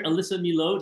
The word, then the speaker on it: Alyssa Milo: Do I say it right or do I say it too much with a French accent, Alyssa? – Yes, good with a French accent. It's like totally French. It's like Merlot.